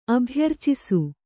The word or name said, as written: ಅಭ್ಯರ್ಚಿಸು